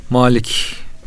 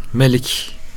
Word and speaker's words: Malik: Malik kelimesi melik de okunuyor hocam. Kıraat olarak iki meşhur kıraat malumunuz. Maliki yevmiddin, meliki yevmiddin diye.